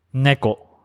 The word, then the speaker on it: neko: Neko. Neko. – Neko. – Yeah, that's right. – Sounds neko. Like a nickel. – Oh, yeah. – Neko. – Neko. – Neko or neck. Neko. – Okay. N-E-K-O.